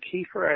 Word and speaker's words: Kiefer: Kiefer.